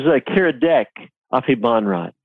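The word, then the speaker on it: Kiradech Aphibarnrat: That is Kirdak Afri Barnrat.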